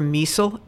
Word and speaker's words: Miesel: You can just Google my name, Sandra Miesel, M-I-E-S-E-L, rhymes with diesel, plus Reformation, and you'll get a link to it.